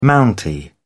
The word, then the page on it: mountie: British English